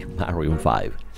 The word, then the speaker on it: Maroon 5: Maroon 5. Sí, Maroon 5.